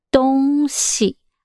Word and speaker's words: 东西: Dong shi. – Yes, dong xi. Actually, dong is east, means east. And xi, this here, we say xi is like a neutral sound.